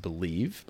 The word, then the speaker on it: BLEAV: It's pronounced Believe, but they've taken some of the vowels out.